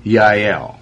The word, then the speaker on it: Jael: Okay, now what we're doing here, there's a woman that's going to be singing a song because Jael, or it's spelled J-A-E-L, but her name is really, in Hebrew you say it how? – Yael. – Yael, okay.